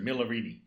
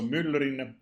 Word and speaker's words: Myllyrinne: My kid brother lives in LA, and when he books a restaurant or something, he just goes, "I'm Mr. Myllyrinne," which is not anything like the original pronunciation.